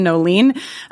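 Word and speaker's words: Noeline: Yes, you can find me most of my social handles are all my first and middle name, which is Kristin Noleen. I'll spell it for you. It's KristinNoeline. It's not Kristin Online, as many people think my name is.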